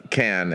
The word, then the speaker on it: Cannes: Hey, sorry to be a snob, but you know it's pronounced can. It's not, the S is, it's French.